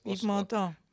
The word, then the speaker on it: Yves Montand: ¿Cómo se dice? ¿Vos sabés francés? – Yves Montand. – Vos así, como lo dijiste francés. Perfecto.